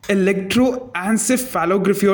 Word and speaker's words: electroencephalography: Anyway, What the fuck is electroencephalography or EG as you shut me down with? – Okay, first let's practice pronouncing it together. It's electroencephalography.